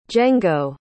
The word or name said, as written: Jengo